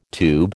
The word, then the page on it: tube: American English